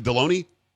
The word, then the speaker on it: Delony: Is it Deloney? – Yes, it was a pretty rough childhood with a name that rhymed with the lunch meat.